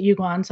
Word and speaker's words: Yuquan: So the dancers are B-Bomb and Yuguan, and Yuguan's kind of hard because in English his name is spelled Yuquan, but it's a Korean name, and it's his name. So he says it Yuguan. So I tend to say it Yuguan.